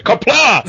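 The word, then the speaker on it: Qapla': Sua pronúncia tá errada, tá? É Kapla!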